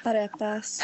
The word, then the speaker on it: arepas: Booth number 12 is La Isla Fresca, and it's arepas. Am I saying that right? – Arepas.